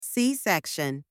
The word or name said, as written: c-section